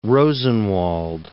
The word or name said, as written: Rosenwald